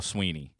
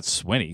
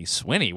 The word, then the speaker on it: Swinney: I think you're wrong about calling the texter wrong, because I think it is Dabo Sweeney. – But I know it's Nagy. – It's not Swinney. Swinney. Where did you call it? It's spelled Swinney. – Swinney. Sweeney, right? – Yeah, I say Sweeney.